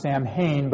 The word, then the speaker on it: Samhain: It says, "In ancient Britain and Ireland, the Celtic festival," so this is up in Northern Ireland, "a Celtic festival of Samhain," it's spelled S-A-M-H-A-I-N. I used to think it was pronounced Samhain, but it's actually pronounced Samhain.